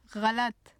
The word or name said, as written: ghalat